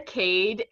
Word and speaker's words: Khaide: But it's Aria Cade, and Cade is spelled K-H-A-I-D-E.